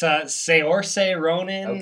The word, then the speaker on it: Saoirse Ronan: Yeah, it's Saoirse Ronan.